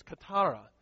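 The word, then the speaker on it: katara (κιθάρα): The actual Greek, here I'm going to pronounce it for you, is katara. It's a word from which we get guitar.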